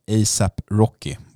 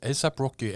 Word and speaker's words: A$AP Rocky: A$AP Rocky. Var det rätt uttal? – Ja, det var det. A$AP Rocky, en av världens absolut största lysande rapstjärnor just nu.